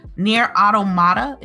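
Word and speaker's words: Nier: Automata: Nier Automata, is that I said it right? – Yeah.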